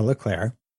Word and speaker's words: Leclerc: And we can assume this because Sainz is usually a bit slower than Leclerc or Leclerc, because we're in Montreal. So I guess I'll give it the French pronunciation.